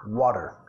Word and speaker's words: water: Y nota, lo pronunciamos en Estados Unidos como una R suave, water, water. Los británicos tienen un par de formas, water and water, water, con el glottal stop.